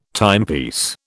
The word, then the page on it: timepiece: British English